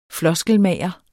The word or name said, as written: floskelmager